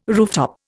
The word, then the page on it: rooftop: Indian